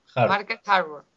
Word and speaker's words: Market Harborough: Market Harborough.